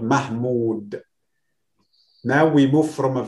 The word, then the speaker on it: Mahmoud: Because now it's a long vowel. Because if I don't add this vowel, it will be Muhammad again. – Yes. – So Mahmoud, then I must add O, long O.